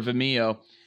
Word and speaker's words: Vimeo: I say it like video, but Vimeo. – Vimeo. I think it's video. It's Vimeo. Video. Vimeo. – It's video. – I said Vimeo. – Yeah, it's video with an M.